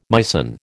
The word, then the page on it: Meissen: American English